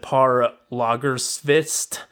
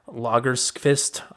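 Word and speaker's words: Pär Lagerkvist: I believe you pronounce it Par Lagerkvist. Lagerkvist.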